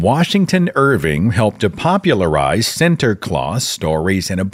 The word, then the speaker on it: Sinterklaas: By the way, St. Nicholas is pronounced in Dutch as Sinterklaas.